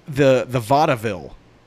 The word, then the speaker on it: vaudeville: The vaudeville.